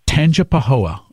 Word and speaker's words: Tangipahoa: Tangipahoa. Tangipahoa.